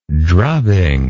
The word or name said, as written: drubbing